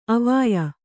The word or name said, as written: ಅವ್ವಯ್ಯ